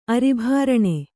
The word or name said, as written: ಅರಿಭಾರಣೆ